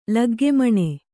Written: ಲಗ್ಗೆಮಣೆ

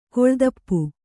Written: ಕಾಲುದೀಪ

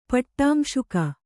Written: ಪಟ್ಟಾಂಶುಕ

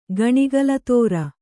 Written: ಗಣಿಗಲತೋರ

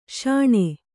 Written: ಶೋಣ

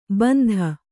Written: ಬಂದಿ

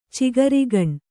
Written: ಚಿಗರೆಗಣ್ಣು

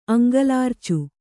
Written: ಅಂಗಲಾರ್ಚು

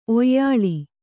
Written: ಒಯ್ಲು

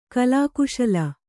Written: ಕಲಾಕುಶಲೆ

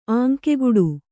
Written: ಆಂಕೆಗುಡು

